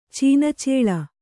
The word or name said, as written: ಚೀನಚೇಳ